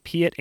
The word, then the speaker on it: Piatt: I think this guy's name is pronounced A. Piatt Andrews. That's A period space P-I-A-T-T Andrews.